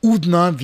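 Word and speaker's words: Oudna: Also im Bardo-Museum kann man wirklich die detaillierte History, Geschichte von dieser wunderbaren Stadt Udna oder Utina oder Udna, wie hier in Tunesien der Name ausgesprochen wird, kann man ja auch dort entdecken und genießen.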